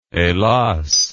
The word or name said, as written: helas